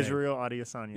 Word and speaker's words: Israel Adesanya: Your guy, Elias Azantes, or I can't pronounce his name. – Israel Adesanya.